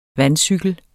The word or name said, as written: vandcykel